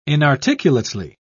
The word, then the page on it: inarticulately: British English